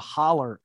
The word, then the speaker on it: holler: So, I mean, we're talking the holler, as they say, in my native state, West Virginia. That's H-O-L-L-E-R is the pronunciation. Hollow is the word.